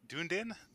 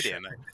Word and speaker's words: Dunedin: Yeah, please let us introduce our guest, former Green Party of New Zealand candidate for, and please correct my pronunciation. No, actually, I can't. – Dunedin. It's Scottish. Dunedin.